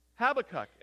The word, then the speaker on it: Habakkuk: Habakkuk. And he is faced with a decision put his belief, his trust, his confidence in God, or reject God and go his own way. In Habakkuk chapter 1, verse 2, he asks a question of the Lord.